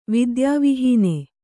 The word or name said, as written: ವಿದ್ಯಾವಿಹೀನ